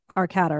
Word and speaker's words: Arcata: Arcata. California. I'm probably saying it wrong. How far does it say how far from Napa it is? – You're talking about Arcata, right? Arcata, yeah.